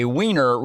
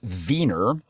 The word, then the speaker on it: Wiener: So when people refer to something as a wiener, and again, hold for your laughs here, but a wiener really should be pronounced Wiener, because the W should be pronounced like a V, and it's referring to it in the style of Vienna.